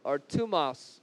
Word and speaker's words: thymos: And the next word is called thymos or tumos is how you would say it in the Greek, but it's spelled T-H-Y-M-O-S.